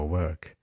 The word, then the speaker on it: work: 工作是一个小口叫 work，那走路呢，圆口 walk，一定要注意。